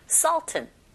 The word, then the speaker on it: Sultan: 네, 우리는 술탄이라고 읽지만 발음은 Sultan 이렇게 하시면 돼요.